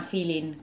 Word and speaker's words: filin: Esta corriente musical, le llamo así, se llama feeling, que se escribe feeling, como suena. – F-I-L-I-N.